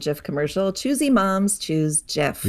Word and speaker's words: GIF: But he very explicitly then and since then repeatedly has said that it's pronounced GIF, and they had in their office a slogan that choosy programmers choose GIF.